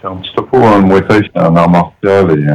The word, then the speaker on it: Muay Thai: Et c'est Muay Thai.